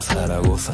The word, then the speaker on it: Zaragoza: Zaragoza, say it right, Zaragoza. Hard, hard, frío, corazón, all in a drip, in a drop.